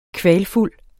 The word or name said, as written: kvalfuld